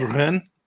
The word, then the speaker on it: Rennes: French, how is it pronounced? – Rennes. – Stade Rennes.